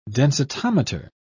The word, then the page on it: densitometer: British English